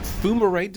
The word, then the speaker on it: fumarate: Fumarate, did I pronounce that right? – Yes.